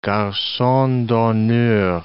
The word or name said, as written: garcon d'honneur